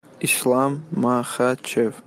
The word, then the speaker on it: Islam Makhachev: Can we hear it one more time? You nailed it. – Islam Makhachev. – So when he slows it down, but it's Makhachev, right? Yeah.